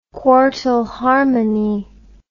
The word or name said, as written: quartal harmony